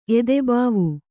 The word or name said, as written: ಎದೆಭಾವ